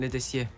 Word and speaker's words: Letessier: Letessier. – Letessier. Entiendo que en francés las consonantes finales no se pronuncian, no se nombran. – El ER se pronuncia E.